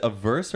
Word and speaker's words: averse: Averse or say adverse. Is it averse or adverse? – A-V-E-R-S. – Averse. – Oh, sorry. – Risk averse.